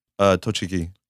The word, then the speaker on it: Tochigi: Tochigi. – Tochiki, the one from the factory, the side view.